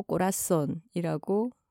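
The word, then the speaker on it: corazón: 아, 김하나 작가가 스페인어 공부를 하고 있고 남미로도 여행을 꽤 길게 갔다 왔었는데 어, 현지어로는 땅고 꼬라손이라고 발음을 하나 봐요.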